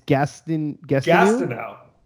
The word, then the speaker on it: Gastineau: How do you pronounce his last name? It's Gastineau. Gastineau. Okay, I've never Frank said it Gastineau.